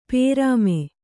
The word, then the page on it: ಪ್ರೇಮಿ: kannada